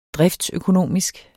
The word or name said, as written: driftsøkonomisk